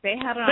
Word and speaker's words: Bejarano: Bejarano.